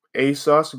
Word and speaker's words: ASOS: Now, as far as ASOS goes, it's spelled A-S-O-S, but it's pronounced A as in A-Y, and then sus as in S-U-S, like suspect or whatnot.